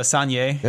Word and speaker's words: Saignée: Now, is there a big difference between skin contact and sanguinee, which I'm sure I'm pronouncing wrong? – Pretty close. I think it's a Saignée.